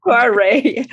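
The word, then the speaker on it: Kvarøy: For more than two decades with brands including Quavroy Arctic. Am I saying that right? – Quavroy.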